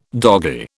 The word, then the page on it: doggie: British English